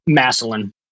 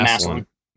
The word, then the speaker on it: Massillon: Is that Massillon? Is that how do you pronounce that town? I've never heard of that. – Massillon. Yeah, Massillon. – Massillon, Ohio.